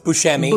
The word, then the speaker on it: Buscemi: Buscemi. No, it's Buscemi. – Buscemi. – Buscemi. – Buscemi. There we go.